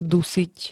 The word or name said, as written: dusiť